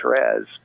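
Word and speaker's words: Thérèse: Now, some people call her Saint Therese, but that's more of a French pronunciation of her name. But in English, of course, we call her Saint Teresa.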